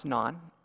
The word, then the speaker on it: Noon: It's the one that looks like noon up there, N-O-O-N. We learned that it's pronounced non, in Senegal.